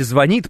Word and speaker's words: звонит: Да, кстати, звонит, правильно. Вот видите, мы еще и ударение прорабатываем с вами.